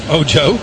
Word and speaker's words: Ojo: Ojo was the intended receiver. That would be Rodney Ojo. Common spelling, O-J-O. – Ojo. – Ojo.